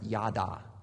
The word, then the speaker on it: yada: The first one would be pronounced yada, yada.